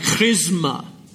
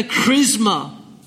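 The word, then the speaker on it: chrisma (χρῖσμα): It's actually pronounced something like chrisma in the Greek. You have the chrisma, the anointing of God upon your head, the anointing of truth.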